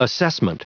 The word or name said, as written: assessment